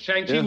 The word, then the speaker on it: Shang-Chi: Shang-Chi was just a surprise.